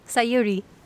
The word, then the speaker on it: Sayuri: Sayuri. – Thank you, Sayuri. I just want to pronounce it uh, correctly.